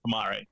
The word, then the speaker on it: Pamare: I was just going to say, I think pronunciation is actually Pamare.